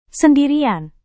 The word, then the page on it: sendirian: indonesian